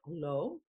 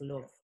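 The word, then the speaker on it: Loewe: It is a W in Germany. So I'm wondering if it is Lowe. Anyway. – Yeah, we've all just been saying it wrong the whole time. And he's like, gosh darn it, people. – I'm gonna find out. Cause now I'm curious for My Fair Lady. Cause here, when it's spelled out phonetically, it has a V in it.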